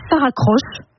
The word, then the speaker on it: Saracroche: Vous avez Saracroche. Petit jeu de mots, hein, ça s'écrit S-A-R-A, ça raccroche.